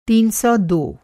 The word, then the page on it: तीन सौ दो: تین سو دو; Hindi